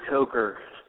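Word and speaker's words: Coker: Is it Cooker or Cooker? – It is Cooker.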